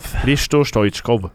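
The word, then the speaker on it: Hristo Stoichkov: Og det uttales, kan ikke du si det, for du er best på språk i fotballuka. Hristo Stojkov. Hristo Stojkov.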